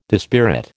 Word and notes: dispirit: American English

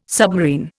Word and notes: submarine: Indian

